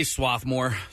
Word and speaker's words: Swarthmore: I say Swarthmore. Yeah. Swarthmore Co-op. – I know there's specifics on pronunciation, so I'll go with you.